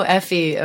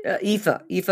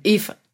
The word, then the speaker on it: Aoife: And I don't know Effie O'Donovan. Is that how you say her name? – It's Aoife O'Donovan. – Aoife.